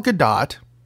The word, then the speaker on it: Gadot: Speaking of powerful women, Wonder Woman herself, Gal Gadot, because you pronounce the T, had some comments about the passing of Dr. Stephen Hawking, which was sad.